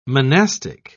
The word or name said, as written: monastic